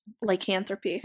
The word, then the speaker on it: Lycanthropy: Lycanthropy? – Lycanthropy.